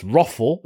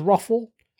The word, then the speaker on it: ROFL: So it's Roffle. Is it Roffle? I think that's... Do you pronounce it as Roffle? R-O-F-L, you know, roll over the floor laughing. Yeah, that.